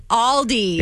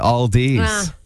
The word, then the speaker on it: Aldi: Aldi's. – It's not Aldi's. It's Aldi. – It's Aldi. Mm-hmm.